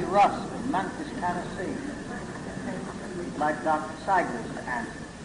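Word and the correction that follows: Sigerist: Dr. Sigrist, I believe it is. I mispronounced it. Sorry. Yes. Dr. Sigrist.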